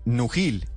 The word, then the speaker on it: Nugil: Yo creo que se dice Nugil. N-U-G-I-L. Ah, Nugil. Sas. – Nujil es ese Gil de Gilinski.